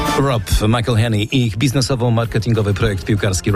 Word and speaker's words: McElhenney: Ways to massacre and mispronounce it, there are many. – McElhenney. – It's McElhenney.